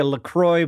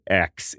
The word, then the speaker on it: LaCroix: It shouldn't even be pronounced LaCroix because it's a French thing, but we've taken French names and made them more American sounding.